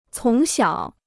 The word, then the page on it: 从小: cóng xiǎo